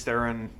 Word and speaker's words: Theron: Theron. Thank you for saying it correctly. Theron. Yes, I saw the interview where she said it's just Theron. It's not Theron for you French-speaking knuckleheads out there.